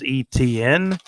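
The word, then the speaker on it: Etienne: I wrote down ETN. That's kind of how you pronounce his name, but it's, you know, it's spelled out Travis ETN. I believe it's spelled ETINNE, but I wrote it out kind of phonetically as ETN, like ET and then an N.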